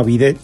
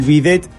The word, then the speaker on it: bidet: ¿Por qué se le llama bidet al bidet? Porque en Francia... – Que además termina en T, entonces no se debía de pronunciar. – Exacto. En Francia hay un caballo chiquito, tipo pony, que se llama bidet, que casualmente ese caballo era el usado por las doncellas para salir a pasear por los jardines.